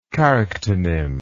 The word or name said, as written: charactonym